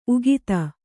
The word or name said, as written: ಉಕ್ಥ